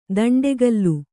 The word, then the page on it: ದಂಡಿಕೋಲು: daṇḍi kōlu